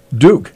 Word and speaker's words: Duk: So he also comes from the town of Duke. And I was asking that question. It's spelled D-U-K. And he says it's like Duke University without the E.